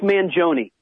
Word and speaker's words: Mangione: I understand the musician Mike Mangione is going to be there. Did I say his name correctly? – Yes, Mike Mangione.